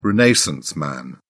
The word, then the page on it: renaissance man: British English